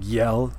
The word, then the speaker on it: Jelle: And there's a YouTube channel called, it's pronounced, or it's spelled J-E-L-L-E, but it's pronounced like Yell. I think it's like, you know, like a North Norse kind of word.